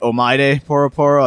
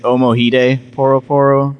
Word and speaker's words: Omoide Poro Poro: Yes, I was saying it Omoide Poro Poro. I fucked up, all right, in the last episode. But if we wanted to really mispronounce it like Americans, we'd be saying it Omohide Poro Poro.